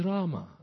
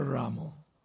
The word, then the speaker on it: Rama: It's always Rama, never Ramo.